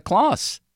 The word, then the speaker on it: Claus: A-U-S is Aus. It's Aus. It's Troy Gloss. I can't believe you just made my argument for me, Donnie. Thank you. It's Santa Claus.